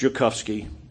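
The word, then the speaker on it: Ziolkowski: And Korchak Joukowsky, it's spelled with a Z, Joukowsky, but it's pronounced Joukowsky.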